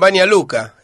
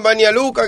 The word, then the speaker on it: Banja Luka: El hombre nacido en Banja Luka, qué sé yo. Banja Luka, dijeron. O sea, mal pronunciado. Banja Luka.